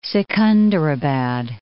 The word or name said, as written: Secunderabad